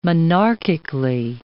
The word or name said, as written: monarchically